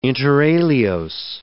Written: inter alios

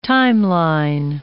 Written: timeline

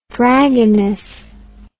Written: dragoness

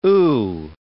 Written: ooh

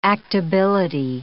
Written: actability

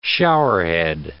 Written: showerhead

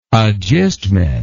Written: agistment

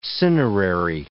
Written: cinerary

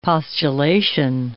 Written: postulation

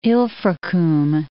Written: Ilfracombe

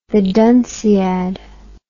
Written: dunciad the

